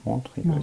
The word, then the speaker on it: Montreux: Habe ich das richtig ausgesprochen? – Montreux. Ja.